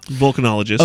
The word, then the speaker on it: volcanologist: Oh, no, I think I was just saying it wrong. But oh, okay. – Yeah, volcanologist.